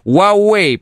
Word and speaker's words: Huawei: Huawei, правильно говорить.